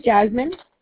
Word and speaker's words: Jasmin: My name is Jasmine. It's spelled J-A-S-M-I-N.